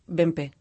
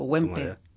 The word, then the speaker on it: Wempe: Helmut Bempe o Wempe. Yo es que le llamo Wempe. Yo le llamo Wempe, pero... Yo le llamo Wempe, pero en alemán es... – Sin pronunciar la W, sí.